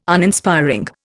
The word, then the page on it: Uninspiring: Indian